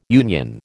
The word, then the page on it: union: American English